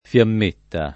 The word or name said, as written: fiammetta